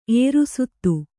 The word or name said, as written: ಏರುಸುತ್ತು